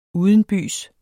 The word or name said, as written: udenbys